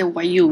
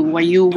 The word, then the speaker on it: Wayuu: So talk to me a little bit about, is it the Wayuu? Is that how it's pronounced? – Yeah, it's the Wayuu. Wayuu women.